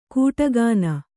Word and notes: ಕೂಟಗಾನ: kūṭa gāna

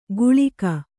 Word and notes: ಗುಳಿಕ: guḷika

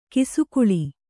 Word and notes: ಕಿಸುಗುಳಿ: Kannada

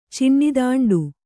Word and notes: ಚಿಣ್ಣಿದಾಂಡು: Kannada

